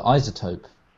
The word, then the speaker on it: iZotope: I just call it iZotope.